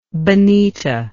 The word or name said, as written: Benita